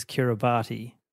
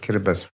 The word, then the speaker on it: Kiribati: I'm about to meet and interview Anote Tong, the former president of Kiribati, usually pronounced incorrectly as Kiribati. – The Republic of Kiribati. – That was Anote with the correct pronunciation.